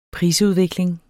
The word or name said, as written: prisudvikling